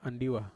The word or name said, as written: andiwa